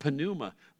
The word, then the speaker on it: pneuma: But in the Greek language, you always pronounce the P. There's no silent P. There's no silent letters at all in most languages of the world. So it's pronounced pneuma.